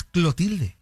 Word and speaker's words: Clotilde: Doña Cleotilde. – Era Clotilde, ¿no? Acá es Clotilde. – Ah, pues tal vez era Clotilde y nosotros lo pronunciábamos.